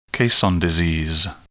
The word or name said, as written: caisson disease